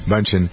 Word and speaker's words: Bündchen: Bündchen.